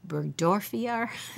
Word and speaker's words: burgdorferi: And the name of the bacteria is Borrelia burgdorferi.